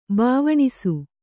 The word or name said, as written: ಬವನಾಸಿ